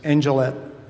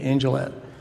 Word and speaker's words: Angelette: And the waitress, or the girl behind the counter, her name was Angelette. I guess that's how you pronounce it, Angel, and T-T-E, Angelette.